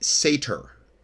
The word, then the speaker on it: Satur: Now, the name Saturn in Chaldee is pronounced Sator, but S-A-T-U-R.